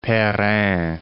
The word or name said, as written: Perrin